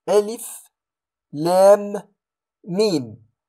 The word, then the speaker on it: الم: أقول ألف لام ميم، عندما أقول لام ميم أدغم تلك الميم للام في هذه الميم هنا،